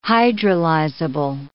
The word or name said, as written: hydrolyzable